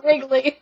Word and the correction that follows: Ridley: It's Ridley.